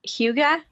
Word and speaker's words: hygge: It's hygge.